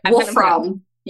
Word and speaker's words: Wolfram: Wolfram.